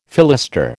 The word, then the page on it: fillister: American English